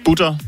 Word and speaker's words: butterdej: Det er butter, ligesom tysk, altså smør på tysk, så butter. – Butter. – Ja, butterdej. Du behøver ikke at overudtale det, vil jeg sige. Men det har, men det har fordi faktisk... engelsk ville jo være butter. – Ja, ja. – Så det nu er det butter.